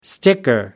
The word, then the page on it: sticker: American English